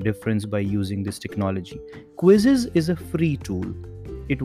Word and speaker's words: Quizizz: And the interesting thing is that its name is also quizzes, but the spelling is slightly different. It is spelled as Q-U-I-Z-I-Z-Z.